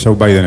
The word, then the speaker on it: Joe Biden: Joe Biden está bien.